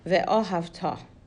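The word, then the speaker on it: Ve'ohavta (ואהבת): So they start the first paragraph of Shema with Ve'ohavta. Okay, that's how you pronounce that word, and it means, and you shall love.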